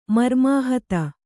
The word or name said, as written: ಮರ್ಮಾಹತ